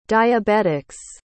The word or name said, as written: diabetics